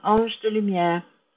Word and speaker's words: Ange de Lumière: I'm Ange de Lumière on LinkedIn and on Facebook, but these are the main two platforms that I'm active on at this stage.